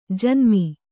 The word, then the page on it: ಜನ್ಮ: janma